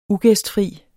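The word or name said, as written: UGÆSTFRIE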